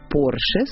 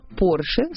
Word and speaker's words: Porsche: Фердинанд Артур родился в Австро-Венгрии в семье судетских немцев, поэтому склонять его фамилию на французский манер в корне неверно. Запишем Porsche с ударением на последний слог, а равно Porsche – ошибка. Правильно говорить Porsche с ударением на «о».